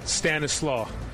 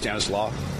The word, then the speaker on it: Stanislas: How do you pronounce it? – Stanislaw. – You don't pronounce the S? – No.